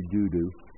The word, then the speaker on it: Dudu: It looks like Dodo, I know. It's pronounced actually doo-doo.